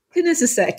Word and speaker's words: Kinesisek: Has made her way to the small village of, and here boy, I'm like, Kinesisek. Is that right? – Kinesisek, yes.